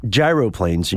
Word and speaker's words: gyroplanes: The gyroplanes, and you are correct, Scott, that is the correct pronunciation.